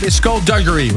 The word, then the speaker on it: skullduggery: Skullduggery, right? – Yeah, skullduggery, yeah. I've had a few funny pronunciations, but it's definitely skullduggery.